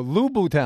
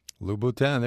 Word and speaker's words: Louboutin: Oh, Louboutin, I'm saying it wrong. – Yeah. – Louboutin, there you go.